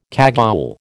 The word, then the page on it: cagoule: American English